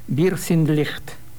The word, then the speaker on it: Wir sind Licht: No, en este caso es Chesudaka con Mal LB haciendo Somos Luz o Wir sind Licht.